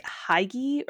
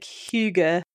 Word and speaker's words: hygge: Juveria rang in, and I think she pronounced it like Heige or something, mm-hmm. which is not how it's pronounced. But, you know, if you don't know, just, you know, any reasonable guess that reflects all the letters that are there in the word, they're going to take. So the actual pronunciation is something like Hygge.